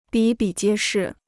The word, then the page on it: 比比皆是: bǐ bǐ jiē shì